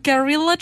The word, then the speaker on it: guerrilla: It's especially hard in the summer when you do the guerrilla training. Is that how you say it? – Guerrilla training. You know, it's your family and there's so much you want to eat. You don't know how to pronounce gorilla.